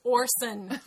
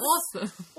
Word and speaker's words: Orson: His name is Orson. With a goddamn R that they don't pronounce. – Awesome.